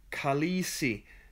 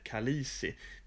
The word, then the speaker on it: Khaleesi: Khaleesi eller Kalisi. Det stavas olika, men det uttalas nästan exakt likadant och jag har lite svårt att seriöst hantera när man använder namnet på ett magsjukevirus som en vördnadsfull titel.